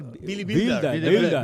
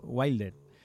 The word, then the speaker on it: Wilder: Es él, bueno, es alemán, pero como de origen alemán, habría que decir Billy Wilder. – Pero bueno, como se nacionalizó norteamericano, pues lo pronunciamos a la inglesa, ¿no? Wilder.